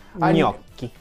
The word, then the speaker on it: gnocchi: Gnocchi. – Like a couple years ago. – What would you have called it before? – I would pronounce the G. I would try so hard.